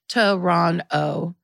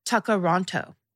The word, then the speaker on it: Toronto: Toronto, pronounced Toronto, or in traditional Mohawk, Tuckeronto.